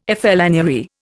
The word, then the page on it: flânerie: Indian